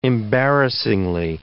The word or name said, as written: embarrassingly